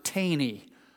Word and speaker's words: Thoene: That's not how they spell it. T-H-O-E-N-E, and they pronounce it Taney.